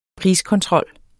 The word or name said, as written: priskontrol